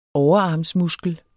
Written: overarmsmuskel

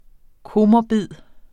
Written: komorbid

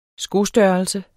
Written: skostørrelse

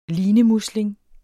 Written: linemusling

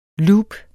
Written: loop

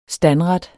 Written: STANDRETTERNE

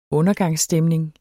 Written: undergangsstemning

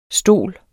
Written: stol

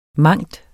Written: mangen

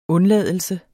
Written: undladelse

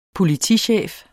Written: politichef

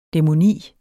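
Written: DÆMONI

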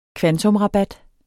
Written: kvantumsrabat

